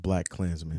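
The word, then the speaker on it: BlacKkKlansman: I think it's pronounced Black KK Klansman. – No, just Black Klansman.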